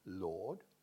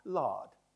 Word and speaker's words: Lord: For instance, today we say the word Lord like this, in RP, I mean, Lord. But in the early 1900s, it was an open vowel, lard, me lards, ladies and gentlemen, lard, like that.